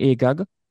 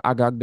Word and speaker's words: Agag: He was actually King Agag or Agag, depending on how you pronounce.